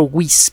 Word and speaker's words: Wyss: también hay un centro que se llama el Centro WIS para bio y neuroingeniería. WIS se escribe W-Y-S-S. Busque eso, WIS, W-Y-S-S, y busque bioengineering, en inglés bioingeniería, en inglés.